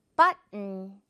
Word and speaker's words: button: 지금 이 가운데 O는 없다고 생각을 하는 거예요. 이게 무슨 말이냐? button이 아니라 but에서 멈춰 하고 그냥 N만 발음을 하는 거죠. 그래서 button 이렇게 되는 거죠. but에서 멈춰 그리고 N만 따로 발음해 주세요.